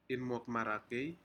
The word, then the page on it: inmokmarakei: Aneityum